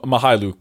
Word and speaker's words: Mykhailiuk: I'm just call him Mykhailiuk because I know that's how you pronounce his last name.